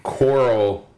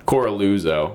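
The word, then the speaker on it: Coralluzzo: So the next day, Dennis Carluzzo, or whatever the fuck his name is. I keep mispronouncing his name wrong, but it's not on purpose. It's just that I don't care. And it's, I think, just it's like Coraluzzo. – Coraluzzo.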